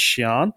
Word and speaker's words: Sian: Um, sorry, this is I think it's pronounced Shian, um, S I A N. Shian.